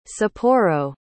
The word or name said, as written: sapporo